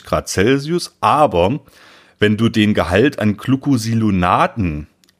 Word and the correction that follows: Glucosinolaten: an Glucosinolaten, jetzt habe ich es richtig ausgesprochen, erhöhen möchtest im Grünkohl 14 bis 17 Grad Celsius.